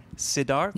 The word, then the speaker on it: Sidharth: Well, a massive congratulations on the release of, don't let me butcher it, Sidharth.